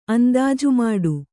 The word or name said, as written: ಅಂದಾಜುಮಾಡು